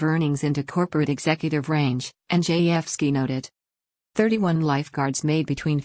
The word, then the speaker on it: Andrzejewski: Andrzejewski, pronounced NG_F_ski, reviewed LA County records and found that the top seven lifeguards made up to $392,000 each in 2019.